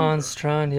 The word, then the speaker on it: Hans Strand: But ha- uh, and I don't even know how to pronounce his name, Hans Strand or Hans. – Yeah, Strand. Hans Strand. Yeah.